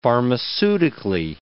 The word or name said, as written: pharmaceutically